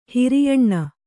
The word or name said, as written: ಹೆರೆಯೆಣ್ಣೆ